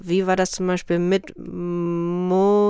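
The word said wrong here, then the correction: Monetotschka (Монеточка): Wie spricht man das äh, richtig aus? Monetotschka. Monetotschka, okay.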